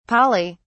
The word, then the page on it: poly: British English